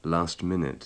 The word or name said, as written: last-minute